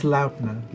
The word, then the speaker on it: Laudna: It's Laudna.